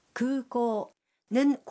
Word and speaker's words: 空港: 구고는 공항이라는 뜻인데 구고의 우와 오는 길게 발음합니다.